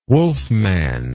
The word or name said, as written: wolfman